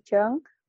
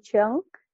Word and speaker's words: Cheung: So my name is Natalie Chung. On Twitter, I am Natalie G. Chung. My surname is spelled C-H-E-U-N-G.